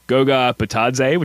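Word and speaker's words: Goga Bitadze: So those names are from Indiana, Goga Batadze,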